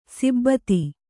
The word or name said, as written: ಸಿಬ್ಬತಿ